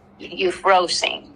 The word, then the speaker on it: Euphrosyne: Thalia, Aglia, and Euphrosyne. – Right. – Euphrosyne.